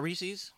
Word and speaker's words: Reese's: It's not Reese's? – Well, his name's not Reese. So an apostrophe S would be Reese's, right? – And, Reese's. – Which rhymes with pieces as it's spelled the normal word.